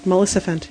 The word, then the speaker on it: Maleficent: Maleficent? – Maleficent.